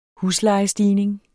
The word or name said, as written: huslejestigning